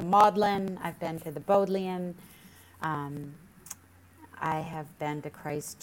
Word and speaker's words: Bodleian: And if you went to Oxford, I'm not sure I know how to pronounce it, but did you go to the Bodleian Library? – You said it right, the Bodleian.